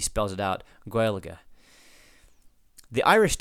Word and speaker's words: Gaeilge: Where I know that, yes, the pronunciation of Gaelic in Irish when you're speaking the language doesn't sound like Gaelic. It sounds like, just as Sam lays it out here, Gaeilge.